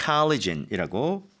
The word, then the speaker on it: collagen: 네, 이거 흔히 말하는 콜라겐인데 yes. – collagen이라고 발음을 하게 됩니다.